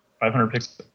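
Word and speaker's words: 500px: How is it pronounced? It says 500. Is it like pixels? – Yeah, it's pixels. – 500 pixels.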